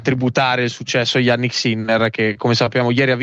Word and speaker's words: Sinner: Poi si pronuncia Sinner, sentito ieri sera a tavola.